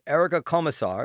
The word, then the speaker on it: Erica Komisar: Her name is Erica Komisar.